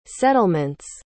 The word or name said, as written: settlement's